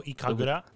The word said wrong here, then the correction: Ikaruga: Ikaruga. – Yeah, Ikaruga. Oh shit. I always thought her name was Ikagura, but yeah. Ikaruga.